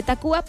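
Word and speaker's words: Tacvba: que también está este cambio en el nombre de la característica U por la V, que de repente no la pronunciamos como debería de ser, sino que directamente decimos Café Tacuba, pero sabemos que se escribe diferente el nombre,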